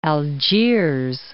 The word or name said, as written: Algiers